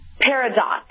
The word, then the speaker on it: Peridot: Okay, again, you said it was Paradog, is that what you said? – Paradot. – Oh, okay, spell that for me. – P-E-R-I-D-O-T.